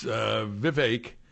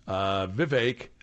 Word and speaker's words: Vivek: Vivek Ramaswamy, and it's Vivek. That is the suggested pronunciation. Vivek, as in cake. It rhymes with cake.